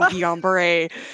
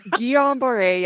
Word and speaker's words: Guillain-Barré: And you did a great job at pronouncing Guillain-Barré. Some people, Guillain-Barré, yeah. Well, it's French, and so it's, you gotta get that French accent, right?